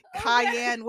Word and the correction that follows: Kanye: Kanye West.